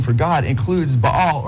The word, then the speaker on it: Baal: And then Baal, or he says Bel, B-E-L, or Baal. He spells it B-A-A-L, how the Bible spells Baal or Baal, it's pronounced in Hebrew, which is the Canaanite god.